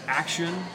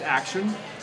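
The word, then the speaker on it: Axeon: You know, talking about domestic teams who've had an impressive run, Action has been Axion, if you're not familiar with the actual pronunciation. It's spelled Axion, but it's pronounced Action.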